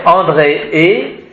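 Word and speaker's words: Andreae: Donc André et ici, A-E se prononce et il y a aussi un E, donc ça fait André et. Ça ne fait pas André A-E, hein, c'est André et.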